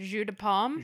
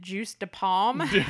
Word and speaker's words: jus de pomme: And I remember for the girls, we ordered apple juice, and you pronounce it jus de pomme. – Jus de pomme. – But at the time, we pronounced it juice de pomme.